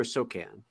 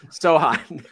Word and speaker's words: Sochan: Is it Sochan or Sokan? – Sochan. – Sohan. – The one I didn't say. Got it. Okay. Yeah. Sohan.